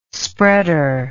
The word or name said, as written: spreader